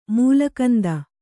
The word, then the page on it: ಮೂಲಕಂದ: kannada